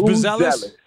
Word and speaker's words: Buzelis: Matas Buzelis.